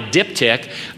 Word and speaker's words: diptych: Well, there was something called a diptych, D-I-P-T-Y-C-H, and it's pronounced diptych.